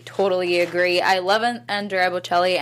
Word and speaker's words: Bocelli: The other two sort of auxiliary performances we got were this father-son duet on the pianos, the Botticellis, is that right? – Am I pronouncing that right? – Bocelli. – The Bocellis, yes.